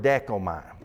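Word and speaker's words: dekomai (δέχομαι): That are basically pronounced something like this. There's a Greek word lambano, and then there's a Greek word dekomai.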